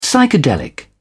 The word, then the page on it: psychedelic: British English